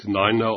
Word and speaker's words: Niner: Empfehlungen wie 9, das wird Niner ausgesprochen, 4, Fourer und 3, eher wie Tree.